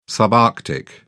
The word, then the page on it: subarctic: British English